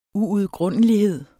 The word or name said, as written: uudgrundelighed